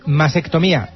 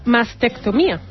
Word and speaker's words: mastectomía: ¿Masectomía? – Claro. – No. – Claro, porque el término adecuado es mastectomía. – Mastectomía. – Claro, porque viene de masto, que es el pecho, ¿vale? – Uh-huh.